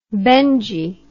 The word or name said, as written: Benji